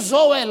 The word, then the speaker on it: Zoe: Z-O-E, pronounced Zoe.